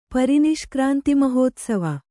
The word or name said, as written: ಪರಿನಿಷ್ಕ್ರಾಂತಿಮಹೋತ್ಸವ